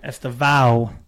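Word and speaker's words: Estêvão: But, you know, it did open the door for, I'm going to say it wrong, Estevao is the Burnley guy. – You're right. Estevao, the Chelsea wonderkin, came in.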